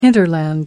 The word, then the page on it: hinterland: American English